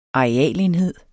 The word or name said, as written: arealenhed